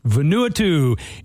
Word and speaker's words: Vanuatu: How do you say it? – Vanuatu.